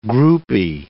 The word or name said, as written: groupie